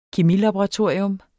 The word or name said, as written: kemilaboratorium